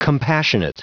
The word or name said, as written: compassionate